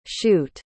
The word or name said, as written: shute